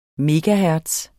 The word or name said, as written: megahertz